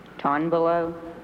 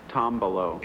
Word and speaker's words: tombolo: Ton below? – No. Listen to the first syllable. Tom below.